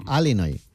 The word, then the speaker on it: Illinois: Se pronuncia Illinois.